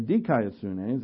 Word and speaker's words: dikaiosune: righteousness, which is dikaiosune, or dikaiosune is actually how it's pronounced, and this is the character of God's rightness and justice.